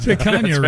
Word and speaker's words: picanha: You know what you did pronounce right in those commercials? Picanha. You pronounced picanha right, for sure.